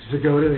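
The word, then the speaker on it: Zgoda: And you are Mark Zagoda, the architect.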